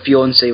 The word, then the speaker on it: fiancé: Let's see, we get a little bit of exposition, like, fucking Kate's pronunciation of the word fiancé was annoying. Fiancé! – Fuck off, that's how it's said. – I know, but it's like she was trying to say Beyoncé. Like, and stuff, fiancé. But it just, like, it had to be said as a, like, three clear syllables.